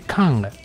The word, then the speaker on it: Cannes: Cannes, que yo otro día es una tontería lo que voy a decir, pero es que se dice Cannes.